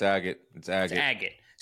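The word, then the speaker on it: Agit: So apparently, we've been pronouncing his name wrong. It's Agate, not Agate. – Yeah, it's Agate.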